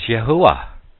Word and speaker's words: Yahuwah: While he had the correct pronunciation in the Hebrew is Yahuwah.